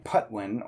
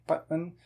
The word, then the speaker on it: Patwin: The way that we're saying Patwin and trying to be sort of close to the native pronunciation there, that has an unaspirated P. So instead of saying Patwin or Patwin, you kind of say it with a muted P, like the Spanish P, Patwin. And that's a Patwin sound.